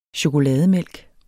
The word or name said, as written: chokolademælk